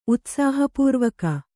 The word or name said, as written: ಉತ್ಸಾಹಪೂರ್ವಕ